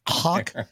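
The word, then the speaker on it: Haack: Now we got Matt Hack, right? – Matt Hack, who is the kid of Isaiah McKenzie. Hawk. – Yeah, that's how it's pronounced. Matt Hawk.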